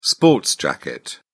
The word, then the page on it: sports jacket: British English